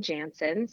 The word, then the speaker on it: Jaenson: So on Instagram, I'm Emily Jansen. So Emily is spelled the old fashioned way, E-M-I-L-Y. But my last name is J-A-E-N-S-O-N.